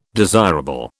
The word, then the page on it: desirable: British English